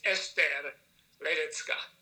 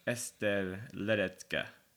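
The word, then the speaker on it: Ester Ledecká: So the A in general, whether, you know, any of these languages, the A is like the sound of A in America. – Gotcha. Okay. – Right. And if you really want to say it correctly, it's Esther Ledecka. – Esther Ledecka.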